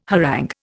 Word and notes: harangue: Indian

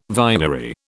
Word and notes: vinery: British English

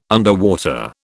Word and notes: underwater: British English

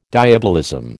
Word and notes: diabolism: American English

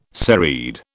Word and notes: serried: British English

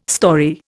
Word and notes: storey: Indian

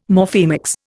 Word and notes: Morphemics: Indian

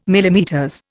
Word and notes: MM: Indian

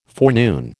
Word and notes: forenoon: American English

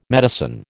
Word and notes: medicine: American English

